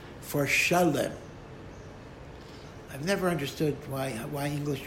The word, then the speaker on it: Shalem: I mean, you could just as easily call it Shalem, which is what the Hebrew pronunciation is, but for some reason, Shalem is translated as Salem.